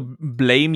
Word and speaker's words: Blame: Also, also es wird Blam ausgesprochen, okay.